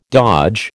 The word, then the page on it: dodge: American English